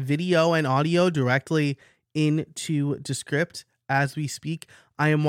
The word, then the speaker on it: Descript: So I'm going to say Descript, like description, description.